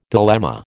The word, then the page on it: dilemma: American English